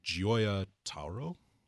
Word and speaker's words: Gioia Tauro: It's Gioia Tauro.